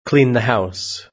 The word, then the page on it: clean the house: British English